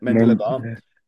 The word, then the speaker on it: Mendilibar: And with one manager coming in José Luis, I can never remember pronounce his name, Mendilibar, that's it, Mendilibar, out goes Pepe Mel at Offi.